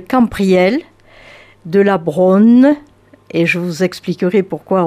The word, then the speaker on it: Braunhie: La Brogne, alors que ça s'écrit B-R-A-U-N-H-I-E, la Brogne, dites-vous. Nom hirsute et bizarre au premier regard, mais familier à tous les Quercinois. On le prononce Brogne, et se lèvent alors des chaînes sans fin, tenaces, tordues, une dense forêt venue tout droit d'avant, même l'histoire.